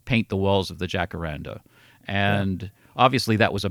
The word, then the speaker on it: Jacaranda: Jacaranda.